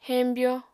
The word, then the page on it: heñbyo: apatani